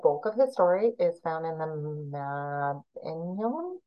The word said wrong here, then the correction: Mabinogion: which I butchered. It's Mabinogion. And it is a collection of Welsh myths, legends, stories.